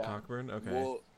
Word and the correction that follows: Cockburn: So, it's actually pronounced Coburn. – Oh, is it? Oh, shit. Yeah. – He told me that because I was like, dude, you're making this shit up. He was like, it's pronounced Coburn.